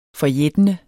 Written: forjættende

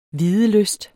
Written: videlyst

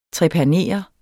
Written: trepanere

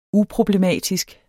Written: uproblematisk

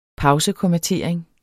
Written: pausekommatering

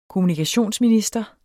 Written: kommunikationsminister

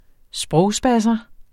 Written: sprogspasser

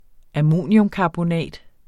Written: ammoniumkarbonat

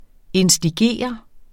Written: instigere

